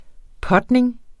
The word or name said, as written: putning